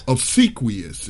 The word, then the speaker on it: obsequious: Obsequious is how you pronounce that.